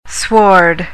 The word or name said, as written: sward